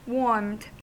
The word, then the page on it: warmed: American English